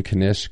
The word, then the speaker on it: knish: J-Hops is saying I'm pronouncing Knish correctly.